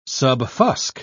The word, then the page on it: subfusc: British English